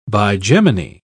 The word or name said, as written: bigeminy